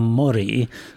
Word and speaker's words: mori: Yeah, I mean, as I said earlier, it's Dulce et decorum est pro patria mori, with a short O.